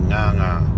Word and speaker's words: nganga: And so today's word is nganga. NG long A, NG long A, nganga.